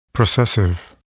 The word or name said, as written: processive